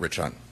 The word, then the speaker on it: Rich Hunt: Lieutenant Rich Hunt. – Can you spell it? – R-I-C-H-H-U-N-T.